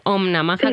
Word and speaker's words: Om Namaha: Om Namaha, es así, ¿no? Como sí. Om Namaha, sí, como sí. Se pronuncia.